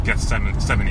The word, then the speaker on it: Gethsemane: is that we all will go through our own Gethsemane, am I saying that right? – Yep.